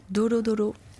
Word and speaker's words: Dolo Dolo: Dolo Dolo, c'est exactement comme ça. – Redites encore. – Dolo Dolo.